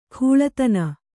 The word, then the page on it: ಕೂಳತನ: kūḷatana